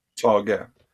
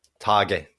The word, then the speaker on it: Tage: Am I saying that right? Tage Erlander. – Tage. – Tage, that's it, yeah.